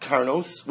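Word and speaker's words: Karnos (קרנות): So Beis Shammai says that even though it's written Vav, it's pronounced Karnos, which is plural.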